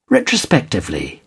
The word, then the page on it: retrospectively: British English